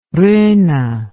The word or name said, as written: ronne